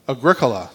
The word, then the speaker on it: Agricola: Agro, ag, how would you pronounce Agricola? Agricola. That was the other one I was going to go with.